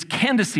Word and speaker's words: Candace: It's Candace, I believe, is the pronunciation.